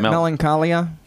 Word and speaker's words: Melancholia: I always say it wrong, the movie Melancholia. It wasn't Melancholia? – Yeah.